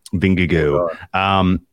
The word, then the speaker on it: Vingegaard: I've settled on Vingegaard as the pronunciation. Like, it's like the G-E-A-U, like Go Tigers LSU. Yeah. Vingegaard.